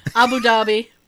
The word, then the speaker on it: Abu Dhabi: Abu Dhabi.